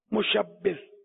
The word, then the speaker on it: مشبر: اعلیٰ حضرت نے اس کا تلفظ ہی بیان کیا, شبر, شبیر, مشبر.